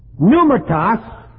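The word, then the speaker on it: Pneumatos (πνεύματος): It is P-N-E-U-M-A-T-O-S. And the accent is on the O at the end there. P-N, it's pneuma, or spirit. P-N-E-U-M-A-T-O-S. And the O is short, os. The accent is right over that, os. Pneumatos. And hagion, or hagion. H-A-G-I-O-N.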